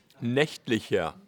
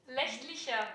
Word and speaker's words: nächtlicher: Nächtlicher. – Nächtlicher, ok. – Nächt, wie die Nacht.